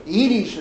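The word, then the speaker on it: Edy's: Eddie's. Edie's, it's pronounced.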